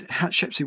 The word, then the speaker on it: Hatshepsut: Is this Hatshepsut,